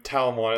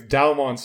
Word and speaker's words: Dalmon: Yeah, it'd be so funny if the ultimate level was just like, "Yes, I am Talmon," and then Henry just yells from back, "It's Dalmon, stupid!"